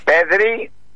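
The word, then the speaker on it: Pedri: Πέδρη, το τονίζω, Πέδρη, Κομπαρσί που είναι 18-19 χρόνο, πόσο είναι? – 19 το...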